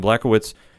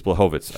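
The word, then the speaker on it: Błachowicz: I mean, Blachowicz is going to go fight John, or actually it's Blachowicz. I always mispronounce his name.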